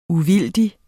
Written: uvildig

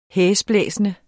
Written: hæsblæsende

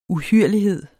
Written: uhyrlighed

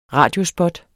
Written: radiospot

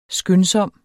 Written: skyndsom

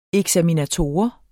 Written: eksaminator

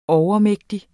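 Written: overmægtig

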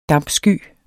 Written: dampsky

